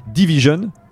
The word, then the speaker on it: DVSN: mais qui s'appelle Division, qui s'écrit D-V-S-N, exactement.